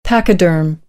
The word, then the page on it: pachyderm: American English